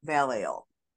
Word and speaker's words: Vallejo: So Spanish speaking people call it Valleno, Yeho, and English speaking people call it Vallejo, California.